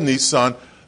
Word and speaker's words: Nisan: Nisan is spelled like the car with one less S. N-I-S-A-N, Nisan.